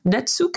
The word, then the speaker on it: Netsuke: So today we are looking at something called, now let me see if I can pronounce this correctly, Netsuke? – Mm-hmm, good job.